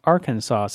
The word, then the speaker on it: Arkansas: Well, he says that's pronounced Arkansas, since apparently there's a bit of debate for that.